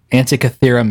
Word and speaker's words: Antikythera: Can you pronounce that? Antikythera mechanism. – Yes, you fucking did it. That's exactly how.